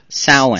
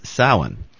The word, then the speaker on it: Samhain: Well, it's actually called, it's pronounced Samhain. – Sow as a female pig in with a natural vowel. So the sound is Samhain.